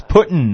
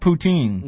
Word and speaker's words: Putin: It's Putin, not poutine.